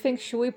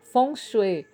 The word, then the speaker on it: Feng Shui: Literalmente, Feng Shui pronuncia-se Feng Shui ou Feng Shui, significa vento e água.